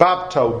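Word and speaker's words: bapto (βάπτω): The Greek word that baptize comes from, baptizo, however you want to pronounce it, it actually comes from a root word, bapto, B-A-P-T-O, is how you pronounce it.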